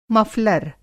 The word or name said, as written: मफ़लर